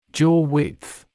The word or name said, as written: jaw width